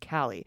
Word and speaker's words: Callie: and our 2.5-year-old mostly blind barn cat rescue named Callie, pronounced like the first part of California.